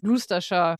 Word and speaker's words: Gloucestershire: Und ich bin so stolz darauf, dass ich das aussprechen konnte, weil es Gloucestershire geschrieben wird. Aber ich weiß, dass es Gloucestershire ausgesprochen wird.